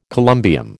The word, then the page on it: columbium: American English